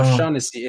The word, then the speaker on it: Shaughnessy: en cuestión proyectado va a ser James Shaughnessy, mm, ahí la pronunciación no... Shaughnessy, exactamente.